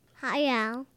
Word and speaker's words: Hayao: Hayao.